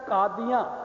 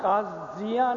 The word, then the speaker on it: कादियां: बहुता जैसा कादियां का शब्द है, कादियां कादियां काजियां नहीं है, काजियां शब्द जड़ा है फारसी का, कादियां अरबी का, तो उसे ज न ददे वांग उच्चारण किया जाता है।